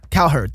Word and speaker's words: Cowherd: Cowherd.